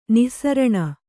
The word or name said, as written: ನಸ್ರಾಣಿ